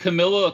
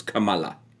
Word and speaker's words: Kamala: Yeah, that's what Camilla or Kamala or whatever she wants to call herself today. Mm-hmm. That's what she wants to do. Now, granted, it's the proper pronunciation is Kamala.